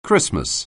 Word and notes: Christmas: anglais

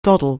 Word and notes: doddle: anglais

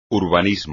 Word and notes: urbanismo: espagnol

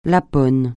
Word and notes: Lapon: francais

